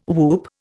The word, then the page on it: whoop: Indian